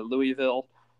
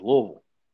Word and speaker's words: Louisville: Louisville. – Oh, Louisville. Sorry. I can't Louisville. Start to pronounce it. Yeah. You got to pronounce it like there's marbles in your mouth.